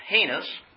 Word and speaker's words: heinous: It's pronounced heinous, not heinous, or heinous, or heinous. Heinous.